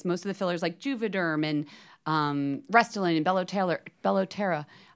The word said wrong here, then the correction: Bellotero: There's one called Bellotera? – Bellotero. I'm saying it wrong, yes.